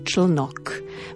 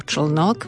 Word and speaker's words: člnok: Pozor, na konci v poslednom odseku máme spojenie tkáčsky člnok. Veľmi ťažko sa hlavne na východe vyslovuje toto slovo člnok, lebo väčšinou je tendencia povedať člnok. Nie, povedzme to pekne po písmenkách. Člnok.